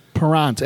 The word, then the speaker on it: Parent: Parent, as in parent. – Oh. No, I missed. – But it's the French pronunciation.